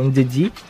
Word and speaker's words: Ndidi: Desarma bem, é o, o volante que mais desarma no campeonato, o jogador que mais desarma, que é o Ndidi.